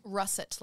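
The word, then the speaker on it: Russett: Russet, like the potato.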